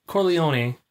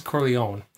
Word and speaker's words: Corleone: Okay, so you pronounce it Corleone. – Yeah. – And I know they do that in the film. I always pronounce it Corleone. – I think it works either way.